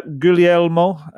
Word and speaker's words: Guglielmo: But Vicario, yeah, Guglielmo, as he told us to pronounce it on his little video he did, it's got everything about him to be a success.